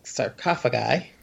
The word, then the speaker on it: sarcophagi: their armor is dead people sar- sarcophagi.